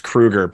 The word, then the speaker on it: Kruger: I'm thinking about getting a t-shirt made that says it's Kruger, but then spell it phonetically.